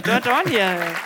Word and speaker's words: Dordogne: Dordogne.